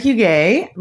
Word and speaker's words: Huguet: Yeah, so my name's Sarah Huguet. Most people don't know how to pronounce my last name. It's like half French, half whatever.